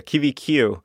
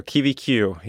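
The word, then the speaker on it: Kiwikiu: Yeah, I pronounce it the kiwiqiu, which I looked and actually did it. They had an official Hawaiian naming several years ago. The original name of it was actually lost over time, as has happened, especially in Hawaiian, which is such an oral language, and how it's passed down. So I looked it up, and that's how they were pronouncing it. It was good enough for me. It's how all the bird nerds out in the woods pronounced it as well.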